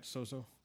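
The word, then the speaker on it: sozo (σῴζω): But in the Strong's Concordance, the word saved comes from a word sozo. Did I say that right, sozo? There you go.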